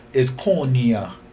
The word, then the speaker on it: koinonia: The Greek word John uses here is koinonia. – Oh, I hear that. – Right? Let me spell it. K-O-I-N-N-O-N-I-E.